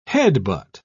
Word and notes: headbutt: British English

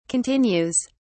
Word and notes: continues: British English

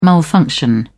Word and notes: malfunction: British English

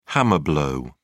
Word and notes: hammer blow: British English

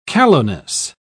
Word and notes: callowness: British English

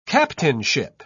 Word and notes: captainship: British English

